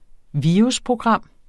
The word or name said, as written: virusprogram